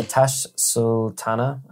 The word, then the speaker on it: Tash Sultana: Today, we've got Terra Firma by Tash Sultana, I guess is how you... – That's exactly how you pronounce it.